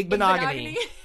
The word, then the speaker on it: Igbinoghene: I can't say cross country, but I can say Igbinoghene.